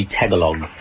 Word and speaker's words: Tagalog: Tagalog, it's kind of spelt. Phonetically, it would be Tagalog.